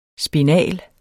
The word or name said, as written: spinal